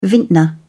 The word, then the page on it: vintner: British English